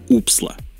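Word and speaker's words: OOPSLA: я практически уверен, что она произносится как «упсла», в течение следующих лет они продолжали работать над Scrum.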